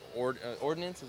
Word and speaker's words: ordnance: All the Skull and Bones lines are named after ordnance. Am I saying that right? – Ordnance, here's my coordinator.